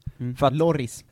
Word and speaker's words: Lloris: Jag skulle ju säga tvärtom, att jag tycker att Hugo Lloris är en trea.